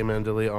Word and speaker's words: Mandile: Mandilli. – Oh, S.J. Yep, I said it wrong. Yeah. Hey, thank you. It's at S.J. Mandilli, M-A-N-D-I-L-E on Twitter. – Yes. It is also at S.J. Mandilli on Instagram.